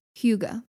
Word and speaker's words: hygge: But it's a Danish word pronounced hygge. It's spelled H-Y-G-G-E.